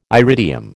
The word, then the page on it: iridium: American English